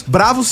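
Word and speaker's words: Bravoz: Então, fica aqui o nosso convite para conhecer o projeto Bravos. Bravos se escreve Bravo com Z no final, beleza?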